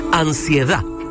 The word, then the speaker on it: Ansiedad: Así, sin de final y con acento en la última A. Ansiedad, a última hora, en Cooperativa.